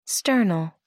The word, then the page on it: sternal: American English